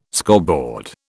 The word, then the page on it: scoreboard: British English